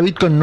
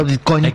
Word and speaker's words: Bitcoin: Que se pronuncia Bitcoin, no Bitcoin, como lo pronuncia Adorni, manifestando lo poco que sabe del tema.